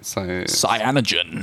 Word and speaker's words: Cyanogen: Cyanogen.